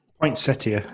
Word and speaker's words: Poinsettia: Poinsettia.